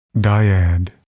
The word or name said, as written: dyad